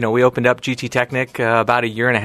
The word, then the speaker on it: Technik: Am I saying Technik right, or should I say Technik? – Technik, pronounced the German way.